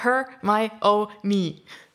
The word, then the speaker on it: Hermione: wie spricht man diesen Namen aus? Und dann war her, my, nie. Oder her, my, oh, nie.